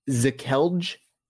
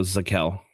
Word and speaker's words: Zekelj: Next, offensive lineman, Nick Zekelj, I believe is how you pronounce his name. – It's the J is silent, so Zekel. – Zekel, okay. 187th overall pick.